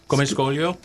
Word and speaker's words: Come scoglio: It says, Come Scoglio.